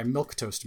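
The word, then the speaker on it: milquetoast: My milk toast mates. I love that word, milk toast. – Yes. – It's so evocative, but it's spelled with a Q-U-E, and so, it's great.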